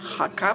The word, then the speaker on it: Jaca: Mitten in der Ortschaft Haka, Achtung, schreibt man J-A-C-A, wird aber als Haka gesprochen, an der Straße Calle Ramiro Primero.